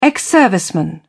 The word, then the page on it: ex-serviceman: British English